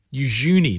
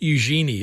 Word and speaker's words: Eugenie: And is it what? Eugenie. Eugenie, not Eugenie, as we've been saying.